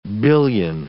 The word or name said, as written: billion